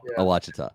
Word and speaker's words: Ouachita: Okay, so let's literally talk about a Ouachita. Did they say that right? – A Ouachita.